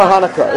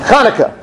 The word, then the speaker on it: Hanukkah: First of all, how do you pronounce it? We're going to get rid of this right now. I want to hear everybody chaying. No more Hanukkah. It's the next level. We cha here. Hanukkah.